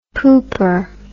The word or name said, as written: pooper